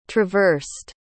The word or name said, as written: traversed